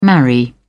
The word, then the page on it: marry: British English